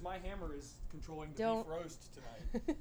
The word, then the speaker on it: Bifrost: Technically, it's roughly pronounced, according to Google, beef roast, but...